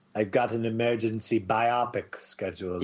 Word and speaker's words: biopic: You, everyone, your mom, your dad, your brother, your sister, anyone and everyone should go see Bohemian Rhapsody, the new biopic on Freddie Mercury and Queen. – Did you just say biopic? – Biopic? How is it pronounced? – Biopic, like biography picture. Biopic.